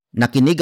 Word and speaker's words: nakinig: For example, nakinig ako ng balita.